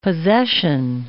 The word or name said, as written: possession